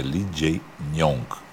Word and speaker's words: Lee Jae-myung: E a gente aprendeu, eu pronunciei errado, é Lee Jae-myung.